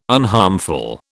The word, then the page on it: unharmful: British English